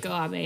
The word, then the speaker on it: Keiko Abe: And that's what I think is so cool about, I'm going to say her name, Keiko Abe, right? – Keiko Abe, yep.